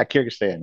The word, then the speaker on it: Kyrgyzstan: Kyrgyzstan.